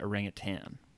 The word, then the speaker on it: orangutan: See, when I worked at Busch Gardens, I'm pretty sure they said to pronounce it orangutan. – Yes, it is orangutan.